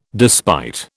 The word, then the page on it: despite: British English